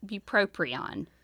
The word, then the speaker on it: bupropion: Bupropion.